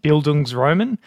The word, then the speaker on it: Bildungsroman: Bildungsroman.